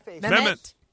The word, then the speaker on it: Mehmet: Mehmet.